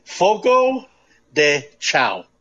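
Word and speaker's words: Fogo de Chão: Is it Fogo de Chao or Fogo de Chao? – Fogo de Chao. – Is it Chao? I heard cow. I hear some people.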